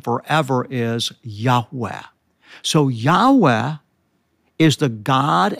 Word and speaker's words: Yahweh: Yahweh. Most Semitic scholars believe it's pronounced that way.